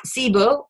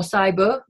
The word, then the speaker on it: SIBO: So a common issue that we're hearing a lot about now in the public, which is good, is SIBO or SIBO, depends how you want to pronounce it, which is a bacterial problem in the small intestines.